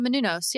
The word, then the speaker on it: Menounos: I don't know how you pronounce it. Maria Menounos. – Oh, Maria Menounos.